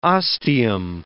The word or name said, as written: ostium